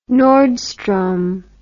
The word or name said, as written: Nordstrom